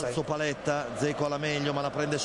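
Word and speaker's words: Džeko: Viene palla, Dzeko, l'ho detto bene.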